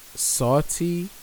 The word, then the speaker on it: Saweetie: It's Sawty. I guess that's how you say it. S-A-W-E-E-T-I-E.